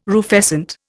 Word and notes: rufescent: Indian

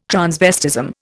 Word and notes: transvestism: Indian

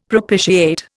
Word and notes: propitiate: Indian

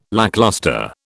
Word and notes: lacklustre: British English

